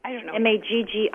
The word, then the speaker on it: Maggi: I think it's pronounced Magi, but I could be wrong. So okay. – Maggi. And it's actually a German condiment that you will find.